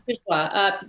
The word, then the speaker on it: Bourgeois: Bourgeois, French artist, but she did a lot of sculpture.